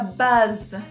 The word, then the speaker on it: buzz: E a pronúncia, que nem a gente já falou, tem os dois Z's no final, você pode até deixar um tempinho a mais quando você for falar. Fica buzz.